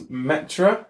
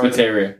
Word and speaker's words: Materia: Whenever you get to the breeding farm for that, you get, and here's the next one I'm going to mispronounce, Metra or Materia? Or it's Materia.